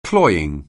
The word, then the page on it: cloying: anglais